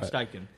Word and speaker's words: Steichen: Shane Steichen. I don't know how to say it. – Shane Steichen.